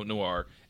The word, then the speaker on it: noir: It had a very noir. I don't know how to pronounce that noir. N-O-I-R.